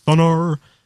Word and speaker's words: Thunar: They've extended, I think you pronounce it, Thunar, their file manager.